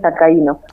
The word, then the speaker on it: Alcaíno: Eh, estamos en contacto con Elena Alcaino, es tía, creo que estoy pronunciando bien tu apellido, si no me corregís, Elena. Eh, un gusto de saludarte. Hola, ¿cómo estás? – Hola, buenas tardes, Carlos. Eh, sí, mi apellido es Alcaíno.